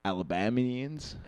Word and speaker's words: Alabamian: I'm a Alabambian. – Alabambian? – Alabambian. Like, what the fuck are they called? – Right. Alabamians.